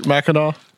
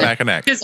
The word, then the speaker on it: Mackinac: I didn't know that either, because I kept looking at it and it looks like it should be pronounced Mackinac because it has a C at the end. But that's an Indian word.